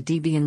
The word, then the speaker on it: Debian: On this day in 1993, Ian Murdoch announced the Debian Linux distribution system. The name combined his then-girlfriend Deborah's name with his own, Debian. And now you know how to properly pronounce it.